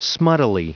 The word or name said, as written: smuttily